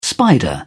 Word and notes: spider: British English

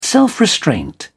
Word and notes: self-restraint: British English

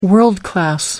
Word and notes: world class: American English

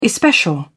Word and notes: especial: British English

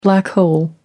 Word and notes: black hole: American English

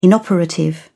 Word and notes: inoperative: British English

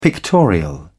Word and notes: pictorial: British English

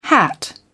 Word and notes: hat: British English

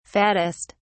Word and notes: fattest: British English

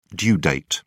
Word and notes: due date: British English

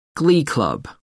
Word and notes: glee club: American English

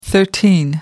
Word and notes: thirteen: American English

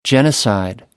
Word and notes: genocide: American English

